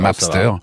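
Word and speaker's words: Mapstr: Je suis d'accord avec vous, c'est difficile à prononcer mais c'est ah, Mapster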